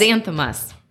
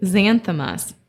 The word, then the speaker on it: Xanthomas: Xanthomas. – How is it? – Xanthomas. X-A-N-T-H-O-M-A-S.